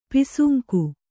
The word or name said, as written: ಪಾಸಂಗ